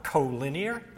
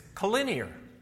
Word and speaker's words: collinear: It was not collinear, as he had been saying. The correct pronunciation is collinear.